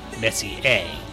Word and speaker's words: Messier: For my Canadian content, I have to add that your review of the hockey game advertisement, Messier is pronounced Messier,